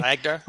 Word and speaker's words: Agder: You are currently also working with the University of Agder. I think that's how you say it, you know, in Norway. – Agder.